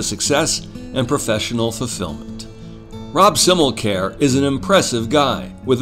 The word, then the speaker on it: Simmelkjaer: Sure, there have been hills and valleys along the way for Simmelkjaer, and by the way, that's how you pronounce it. It's Danish, and when you see it in print, you're going to need a pronunciation guide.